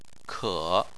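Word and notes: ke: tone 3

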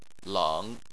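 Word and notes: leng: tone 3

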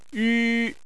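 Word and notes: yu: tone 1

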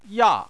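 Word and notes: ya: tone 4

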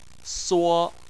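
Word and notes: suo: tone 1

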